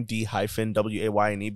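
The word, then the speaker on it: DeWayne: I put a hyphen in my name, D-WAYNE, because my name is pronounced D-Wayne. – Right. – But there's an E in it. It's capital D, little E, capital W-A-Y-N-E. Still pronounced Dwayne.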